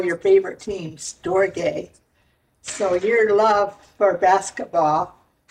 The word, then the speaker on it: storge: Storge, is that how you say it? – Yeah, okay. – Storge, and that's a love that is specifically for parents and children.